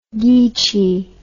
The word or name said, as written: geechie